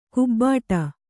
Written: ಕೊಬ್ಬಟ್ಟು

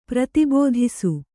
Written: ಪ್ರತಿಬೋಧಿಸು

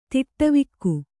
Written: ತಡವಿಕ್ಕು

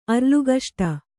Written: ಅರ್ಲುಗಷ್ಟ

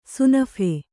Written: ಸುನಫೆ

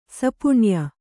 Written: ಸಪುಣ್ಯ

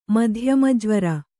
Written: ಮಧ್ಯಮಜ್ವರ

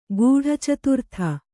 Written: ಗೂಢಚತುರ್ಥ

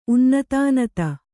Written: ಉನ್ನತಾನತ

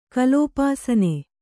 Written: ಕಲೋಪಾಸನೆ

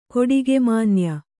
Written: ಕೊಡಿಗೆಮಾನ್ಯ

